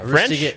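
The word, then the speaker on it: Ruse de Guerre: How would you pronounce the subtitle? Roustigary? – Yeah, Roustigary. Yeah, it's French. Yep.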